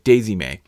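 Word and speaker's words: Dei'zi-Mei: If you went back to Daisy May, the character's called Daisy May, there's a whole bit about how it's spelt like D-E-I apostrophe Z-I hyphen M-E-I, you know, but it's, yeah, Daisy May.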